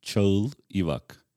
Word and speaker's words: Cagil Ivak: So my name is in full, is Cagil Ivak. – Uh-huh. – Cagil Ivak. – Cagil Ivak. – Cagil has three letters that don't exist in English language.